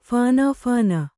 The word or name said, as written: ಫಾಣಾಫಾಣ